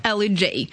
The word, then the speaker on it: L.E.J.: Надо ее произносить вот так вот на английский манер. – L.E.J.